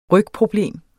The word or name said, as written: rygproblem